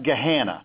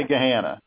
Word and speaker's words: Gahanna: Our detective bureau at the time, it's pronounced Gahanna. – If you can say banana, you can say Gahanna. But you made the same, and a lot of people make that error in pronouncing it. But it's Gahanna.